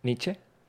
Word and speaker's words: Nietzsche: Nietzsche. Ah. No sé si lo pronuncio bien. – No, sí. – Sí, está bien.